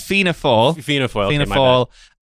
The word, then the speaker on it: Fianna Fáil: Fiona Foyle? – our Scotland correspondent. – Did I say that right? – Foyle. – There's a yeah. So it's Fianna Fáil, and Fine Gael.